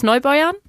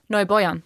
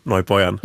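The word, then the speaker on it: Neubeuern: Darf ich die Aussprache korrigieren? – Warte, nein, Neubäuern. – Neubäuern. – Ich so weiß doch. Ich weiß, gut. Ich muss es ja einsprechen in der Synchro. Da wurde ich korrigiert. Neubäuern.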